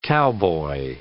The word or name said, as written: cowboy